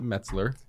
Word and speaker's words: Metzler: I get a lot of Meltzers, so I really appreciate that you actually pronounced it Metzler. – Metzler. – Like New York Mets.